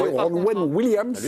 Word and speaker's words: Ronan Williams: La réaction du gardien Ronan Williams, je ne sais pas si je l'ai bien prononcé. Ronan Williams. – Lui, c'est son match. – Il est bien prononcé, mémé.